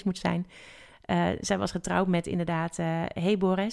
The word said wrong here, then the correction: Heber: zeg ik dat goed? Heber, ja. Heber, ja.